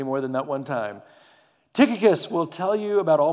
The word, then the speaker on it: Tychicus: Now, if you want to pronounce his name right, it goes like this Tychicus.